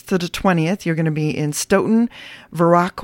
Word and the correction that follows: Viroqua: Oh, I'm saying that wrong. I know Verac. – It's It's all right. I'm from Wisconsin. I can tell you it's Viroqua.